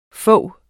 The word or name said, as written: fog